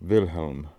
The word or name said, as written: Wilhelm